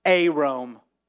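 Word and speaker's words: Arom (ערום): Arom. Wait. Who noticed something about the word for naked? Arom. Arom sounded a lot like what other word? – Arom.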